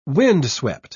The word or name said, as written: windswept